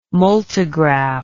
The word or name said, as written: multigraph